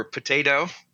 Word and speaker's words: Petito: Yes, my name is Tyler Petito or Potato, depending on who you talk to.